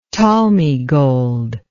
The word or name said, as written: talmi gold